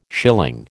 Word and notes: schilling: American English

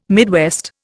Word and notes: Midwest: Indian

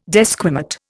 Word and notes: desquamate: Indian

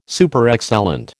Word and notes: Superexcellent: American English

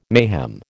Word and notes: mayhem: American English

